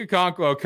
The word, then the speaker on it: Okonkwo: I'm not going to try to pronounce his entire Okonkwo. – Name. – Okonkwo.